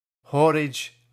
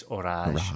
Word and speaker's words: Horage: It's Orage, or Horage. Horage, as rhymes with porridge, but actually it's Orage.